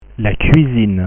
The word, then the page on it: la cuisine: French